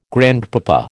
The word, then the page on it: grandpapa: American English